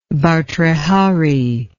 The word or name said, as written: Bhartrihari